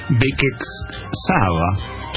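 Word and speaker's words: Békéscsaba: Un día, la madre era una costurera de, presten atención en la ciudad de Hungría, Bekex Saba, Qué bien pronuncias el húngaro.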